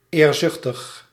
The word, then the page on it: ambitieux: [ɑ̃.bi.sjø]; France